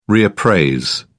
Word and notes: reappraise: British English

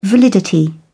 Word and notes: validity: British English

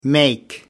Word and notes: make off: American English

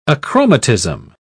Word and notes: achromatism: British English

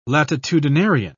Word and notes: latitudinarian: British English

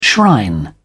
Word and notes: shrine: British English

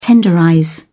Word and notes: tenderize: British English

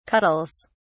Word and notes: cuddles: British English

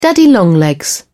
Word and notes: daddy longlegs: British English

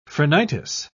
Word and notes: phrenitis: British English

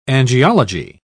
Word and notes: angiology: British English

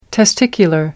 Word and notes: testicular: American English

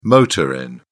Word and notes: motor inn: British English

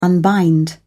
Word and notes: unbind: British English

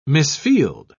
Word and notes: misfield: British English

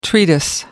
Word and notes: treatise: American English

